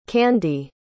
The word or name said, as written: Kandi